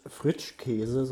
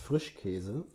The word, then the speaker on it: Frischkäse: ja auch nicht Fritschkäse, sondern du sagst Frischkäse. Und so musst du das auch aussprechen.